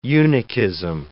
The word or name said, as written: eunuchism